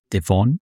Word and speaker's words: Devon: So erstmal haben wir jetzt gelernt, dass es nicht Devon heißt, sondern das heißt Devon. Also müssen wir mit der Betonung noch ein bisschen arbeiten.